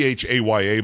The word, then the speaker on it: Chaya: and it's spelled C H A Y A, but she pronounces it Haya. It's more like Haya, but I don't, I can't do that whole throat thing.